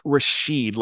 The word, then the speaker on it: Rashid: We've got to go with a Boston accent there. It's Hamika, and it's Rashid, like Rashid Wallace.